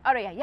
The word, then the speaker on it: Aurier: Is that how am I saying that right? – Aurier, yeah.